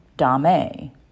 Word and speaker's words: domme: What's the difference between a dom, D-O-M, and a dom, D-O-M-M-E? Dom, D-O-M, short for dominant. A dom, D-O-M-M-E, is the femme version. Some pronounce it dom, some dame. I pronounce it dom. I identify as a dom, D-O-M, or dominant.